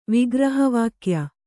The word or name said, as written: ವಿಗ್ರಹವಾಕ್ಯ